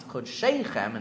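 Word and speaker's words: Chod Sheichem (חדשיכם): And Chod Shechem, which is of course pronounced Chod Sheichem in the plural, is spelled Chod Shechem as if it's only one.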